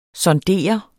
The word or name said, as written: SONDERET